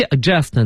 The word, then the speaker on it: adjust: 对，所以是 adjust 呢，它前面一个 d 啊，它就不发音了，但 ad 呢是个强调，那就是哎让你变得特别的正确，所以就是我们说的校正、校对。